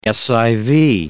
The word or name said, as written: SIV